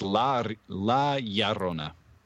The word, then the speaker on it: La Llorona: No, it's La Llorona. – Say it again. – Yeah. – I'm just not going to say it at all during this La recap. Llorona. – Fine. – La Llorona.